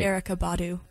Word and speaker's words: Erykah Badu: Kamasi Washington, Kirk Knight, Taylor McFerrin, Erykah Badu. I can never say that right. – Erykah Badu. – There we go.